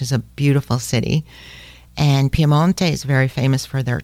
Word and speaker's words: Piemonte: Quickly, to the west of Milano is Piemonte, also as they pronounce it in Italian. The Americans in English say Piedmont, which is almost as bad as Lombardy.